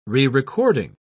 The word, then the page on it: rerecording: British English